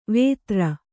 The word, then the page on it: ವೇತ್ರ: Kannada